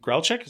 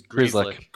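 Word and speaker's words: Grzelcyk: And pulling up the thing, Lindholm and Grelchik, is that how you pronounce his name? – I think it's Grieslick.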